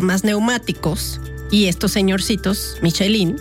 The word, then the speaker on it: Michelin: ¿Ustedes saben saben lo que es la guía Michelin o Michelin, como se quiera pronunciar, porque en francés es Michelin, pero en inglés es Michelin.